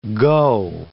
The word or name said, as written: go